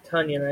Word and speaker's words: Tonyan: Uh, Tonyan, I guess, is the proper pronunciation.